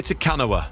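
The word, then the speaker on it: Te Kanawa: Takwani? – Yeah, I'll give you that. You were almost there. It was Kiri Takanawa.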